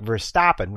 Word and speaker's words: Verstappen: Uh, with the proper pronunciation, unlike Verstappen, which does not have the st in it, just by the way, in case you were wondering.